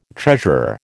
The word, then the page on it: treasurer: American English